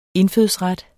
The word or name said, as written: indfødsret